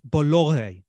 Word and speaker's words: Bolloré: The family that owns Vivendi are a French family by the name of Bolloré. I think that's how you pronounce it. It's spelled B-O-L-L-O-R-E. Okay, I might be pronouncing it wrong.